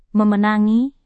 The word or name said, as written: memenangi